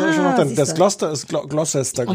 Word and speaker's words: Gloucester: Spricht man Gloucestershire Gloucester aus? Ja, ja. ne? Habe ich immer gefragt, warum da so viele Buchstaben hinten dran sind, wenn man die dann gar nicht hört.